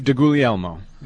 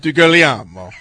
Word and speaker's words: DiGuglielmo: It's Dave DiGuglielmo. It's Italian. He's a Pisan. – You have to say it like that, DiGuglielmo.